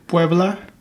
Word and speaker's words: Puebla: P-U-E-B-L-A. How you say that? – Puebla. – Puebla, yeah.